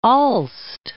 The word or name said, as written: Aalst